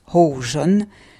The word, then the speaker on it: Hohzo: Alors on prononce Ho-Jon, mais ça s'écrit H-O-H-Z-O.